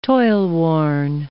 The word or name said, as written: toilworn